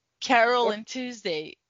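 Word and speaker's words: Carole & Tuesday: É, e eu tô revendo Kiaroru to Tuesday, como é que fala? Carol and Tuesday.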